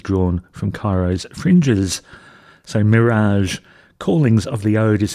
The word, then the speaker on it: Mi3raj: I think they're supposed to just be pronounced Mirage, but it's spelt M-I-3-R-A-J, but seems to be Mirage.